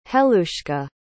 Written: helushka

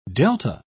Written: delta

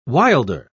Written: wilder

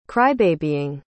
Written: crybabying

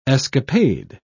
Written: escapade

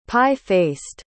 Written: pie-faced

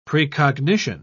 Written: precognition

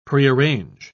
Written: prearrange

